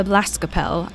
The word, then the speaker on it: Blaskapelle: And also the Blaskapelle. I hope I pronounced that correctly. – There you go. Very good.